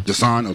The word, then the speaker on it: Dasan: Dasan. – Dasan, okay.